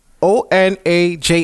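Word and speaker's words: Onajh: Onaj. – Okay. I ain't messing his name up, but shout outs to him. – Onaj. – Well, I think it's Onaj.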